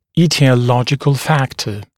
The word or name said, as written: aetiological factor